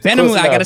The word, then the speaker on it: Benhamou: Benhamou.